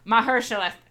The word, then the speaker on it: Mahershala: Mahershala Ali, thank you.